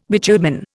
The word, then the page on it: bitumen: Indian